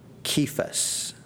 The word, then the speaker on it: Kephas: You shall be called Kephas," is the way to pronounce it, which is translated a stone.